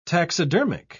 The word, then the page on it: taxidermic: British English